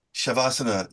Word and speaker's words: Shavasana: Shavasana. And am I saying it correctly? – You are saying it correctly. Shavasana is a Sanskrit word, which consists of two smaller words.